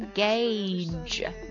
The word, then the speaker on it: gauge: 它的发音是 gauge， gauge， gauge。猜一下它的写法。 G-A-U-G-E gauge gauge 这个单词它可以作为名词表示计量器、测量器、测量仪器、标准的尺寸或者容量的规格等等。